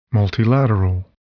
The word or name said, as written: multilateral